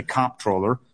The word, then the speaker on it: comptroller: I happen to say comptroller. I hit it hard on the first syllable.